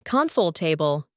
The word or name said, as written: console table